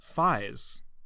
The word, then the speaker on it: Faiz: Oh, Kamen Rider is 555 five is pronounced Faiz. O O O O's.